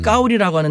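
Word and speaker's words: 까오리라고 (高丽): 시진핑 주석이 만나기 전에 7인자인 장가오리 그 사람은 그 사람의 한자 이름이 우리의 고려시대의 고려랑 같습니다. 발음을 음. 까오리라고 하는데 나는 까오리 고려라는 이름을 갖고 있어.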